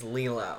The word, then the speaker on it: Lilo: And, shout out Lilo and Stitch. It is Lilo.